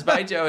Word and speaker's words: Baijiu: It's B-A-I-J-I-U.